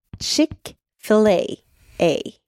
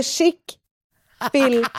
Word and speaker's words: Chick-fil-A: på ett lunchmöte på Chick-fil-A.